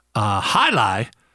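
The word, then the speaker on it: jai alai: Hi-Li, which of course is spelled J-A-I, new word A-L-A-I,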